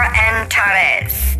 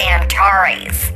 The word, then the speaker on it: Antares: Things are moving towards that war star Antares. That's what it's called. A lot of people pronounce it Antares. It's Antares.